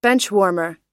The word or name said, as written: benchwarmer